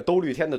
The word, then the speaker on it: 兜率天: 这个兜率天的读音也有同学给我纠正过。其实，嗯，就读兜率天吧，也应该就读兜率天。